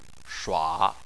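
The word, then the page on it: shua3: tone 3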